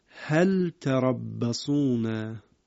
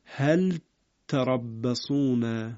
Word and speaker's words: هل تربصون: در سوره مبارکه توبه آیه 52 باز این از اون مواردی است که قدری تلاوتش دقت میخواد، هل تربصون. اینجا به لام حرکت نمیدیم، دقت بفرمایید، لام رو به صورت لام ساکن تلفظ میکنیم اما ت رو مشدد میکنیم، یه قدری تلاوتش دقت میخواد. هل تربصون، من تکرار میکنم، هل، دقت بفرمایید، هل تربصون.